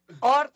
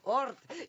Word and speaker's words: ORT: Soy Sofía Sánchez Navarro y tengo en cabina a Avi Meir, director general del Colegio Israelita de México, ORT, ¿lo dije bien? – ORT, ¿por qué siempre lo digo mal? ORT.